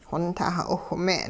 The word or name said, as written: xontah 'iwhme'n